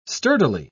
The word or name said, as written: sturdily